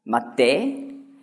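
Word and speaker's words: Matthä (מַטֶּה): Auf Hebräisch schreibt man Stab gleich wie Bett. Matthä. Spricht man es allerdings aus, wenn es Stab heißen soll. Andere Vokale werden eingesetzt in die Konsonanten. Und für Bett Mitta.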